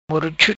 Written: Moro Chut

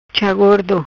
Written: Cha Gordo

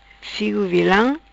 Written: Figo Vilam

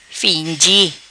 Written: Fingi

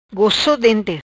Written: Dente gosso

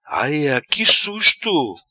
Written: Aia qui susto

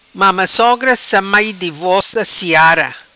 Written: Mama Sogra Sa Mai

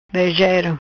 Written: Brejero

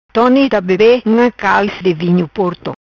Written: Calis Tone

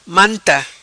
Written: Manta

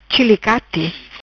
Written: Chilicate

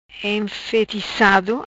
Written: Enfeticado

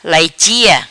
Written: Laichia

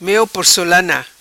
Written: Meo porcolana

